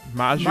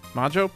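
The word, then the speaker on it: Majyo: Um, the backstory is that uh, the the witch Maggio M A M A M A J Y O. I don't know how to pronounce that. – Magio. Magio. Ma- Maggio. has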